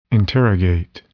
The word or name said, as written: interrogate